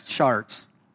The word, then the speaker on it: Chartres: I at one time could pronounce French words, and this one ends in an RTR. And I listened to it, the pronunciation of it, and I still won't say it right, but C-H-A-R-T-R-E-S, charts.